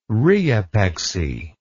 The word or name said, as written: rheopexy